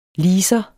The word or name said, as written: leaser